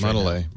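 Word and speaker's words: Mondelēz: The Z is silent. – They put a bar over the E like E. It's supposed to be Mondelez. – No, it's Mondelez. – Mondelez would be the French pronunciation.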